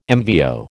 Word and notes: MVO: American English

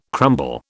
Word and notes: crumble: British English

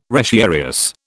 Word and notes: retiarius: British English

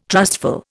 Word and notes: trustful: Indian

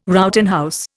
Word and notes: Rowton house: Indian

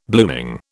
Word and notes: blooming: British English

